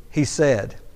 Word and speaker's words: hesed: The Hebrew word there, loving kindness, is the word hesed. H-E-S-E-D. Sometimes it's spelled C-H-E-S-E-D.